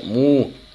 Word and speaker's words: MW: MW, por cierto, se pronuncia Mu, que es el nombre de un gas con devastadores poderes.